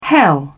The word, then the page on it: hell: British English